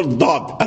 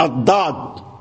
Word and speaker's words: الضاد: كثير من الناس يقول الضاد الضاد انظر الضاد تيوصل لسانه هنا الضاد ملي كيبغي ينطق بالضاد تيحط لسانه هنا الضاد هل النطق هذا صحيح لا النطق هذا غير صحيح إنما الضاد هكذا الضاد الضاد أض